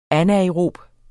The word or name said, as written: anaerob